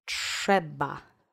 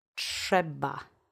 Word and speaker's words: Trzeba: Accent is on the first syllable. Trzeba. Trzeba.